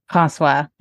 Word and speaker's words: François: How do you pronounce his name? – François. – Yeah, I'm going to say like François. – That's totally fine.